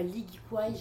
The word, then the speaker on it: ikigai: Ça me fait penser, vu que tu parles de dessin un peu comme ça et de mantra, à l'ikigai japonais. T'as déjà vu l'ikigai.